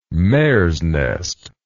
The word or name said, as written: mare's nest